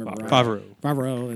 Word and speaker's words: Favreau: I mean, those guys that are running it, John Favreau, I can't say his name. – Favreau.